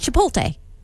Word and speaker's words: Chipotle: Yeah, it's Chipotle.